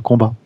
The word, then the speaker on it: Mortal Kombat: Par contre, tu prononces toujours le T à la fin de Mortal Kombat. Oui, parce que c'est un jeu euh, anglais.